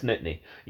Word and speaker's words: Nittany: It was something to do with Penn State, and I was like, is it Nittany? Like, Nitta? And she's like, oh, it's Nittany. You don't pronounce the A.